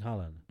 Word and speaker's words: Haaland: And Haaland, Erling Haaland. Is that how you say it? – Yeah.